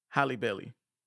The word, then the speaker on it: Halle Bailey: I just want to make sure I say that correct. – You said her name right. – I did, but you know you want to catch yourself because it's so close to Halle Berry.